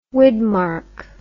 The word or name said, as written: Widmark